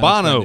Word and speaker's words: Bono: I never know how to pronounce it. – It's Bono.